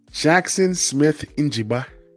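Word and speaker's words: Jaxon Smith-Njigba: Jackson Smith Njigba.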